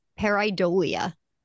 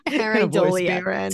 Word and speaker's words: pareidolia: The word again, hold on, I'm going to make sure I say it correctly. Pareidolia, according to the rando voice on Merriam-Webster. Pareidolia.